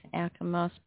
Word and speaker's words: Alkimos: D-E-C-A. – D-E-C-A. – E-U-R. – E-U-R. Street. So what was the suburb again? – Alkimos. – How do you spell that one? Sorry. – A-L-K-I-K-A-L-K-K-I-M-O-S. – M-O-S, Alkimos, perfect.